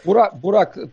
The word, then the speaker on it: бурак: Бурак так произносят в Белоруссии, значит, а на Украине буряк мягко через «я».